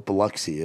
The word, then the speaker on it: Biloxi: How do you say, is that how you say it? – That's how Fred like, Durst. That's Biloxi. How Fred Durst says it. It's in a song with Aaron Lewis. So I say Biloxi because the whole world doesn't know what Biloxi is, you know.